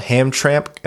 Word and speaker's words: Hamtramck: Everett Johnson says, my favorite part of the last episode was Ryan's pronunciation of Hamtramck, which it's spelled Hamtramck, and I haven't seen that word before, but apparently it's pronounced Hamtramck.